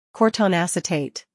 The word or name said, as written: Cortone Acetate